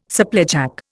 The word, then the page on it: supplejack: Indian